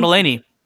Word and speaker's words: Mulaney: Mulhaney? Oh, John Mulaney.